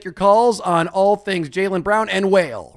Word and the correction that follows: Wale: Otherwise known as the properly pronounced Wale.